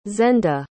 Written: zenda

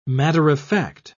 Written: matter-of-fact

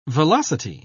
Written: velocity

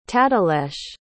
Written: tadelesh

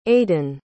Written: adin

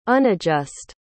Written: unadjust

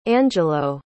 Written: angelo

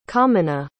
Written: comyna

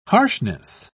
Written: harshness

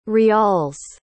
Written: riyals

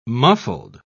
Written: muffled

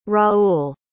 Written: raul

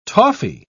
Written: toffee

